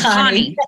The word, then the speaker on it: Connie: It's Connie.